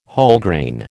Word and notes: Wholegrain: American English

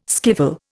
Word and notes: skiffle: Indian

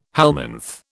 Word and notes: helminth: British English

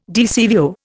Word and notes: DCVO: Indian

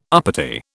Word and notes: uppity: British English

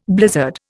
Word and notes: blizzard: Indian

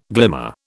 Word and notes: glimmer: British English

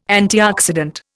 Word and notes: antioxidant: Indian

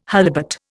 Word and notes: holibut: Indian

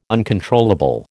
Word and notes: uncontrollable: American English

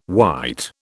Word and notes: white: British English

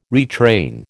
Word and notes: retrain: American English

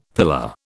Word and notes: pillar: British English

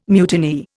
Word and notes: mutineer: Indian

